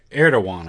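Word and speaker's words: Erdoğan: I don't know if you caught it, but I was kind of amazed at Ergo, what is how do you pronounce the name? Ergoman, the Turkish Prime Minister. – Erdogan, I think it says.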